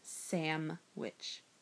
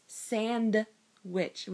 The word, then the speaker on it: sandwich: I can't stand when people pronounce sandwich as sam-witch with an M. There's no fucking M in the word sandwich. Seriously. It's not there. It's a non-existent letter. It's sand-witch with a D, not an M.